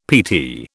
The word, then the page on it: PT: British English